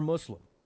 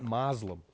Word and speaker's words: Moslem: Now, what are the odds that the perpetrators of this crime are Muslim? Did I say Muslim? I meant Moslem. I prefer the traditional English pronunciation of the title of their religion. They're Moslems, pronounced M-O-Z-L-E-M, Moslem.